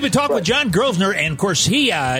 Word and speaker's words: Grosvenor: Anyways, um, listen to John, and his last name is Grosvenor. Don't pronounce the S when you call him; he'll get upset.